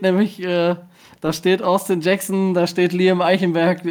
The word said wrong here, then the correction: Eichenberg: äh Eichenberg, Eichenberg, äh, äh, nee, Eckenberg, genau so. Liam Eckenberg wird er ausgesprochen.